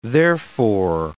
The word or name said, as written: therefore